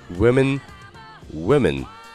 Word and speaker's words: women: 好了，那今天讲了女人的单复数的正确的发音，单数是 woman，woman，复数是 women，women。